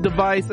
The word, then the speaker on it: DEV_IS: So there is no longer Hololive Japan, no longer Hololive English, no longer Hololive Indonesia, no longer Hololive device, which is not spelled actually device, D-E-V-I-S. Devis, device, it's pronounced device.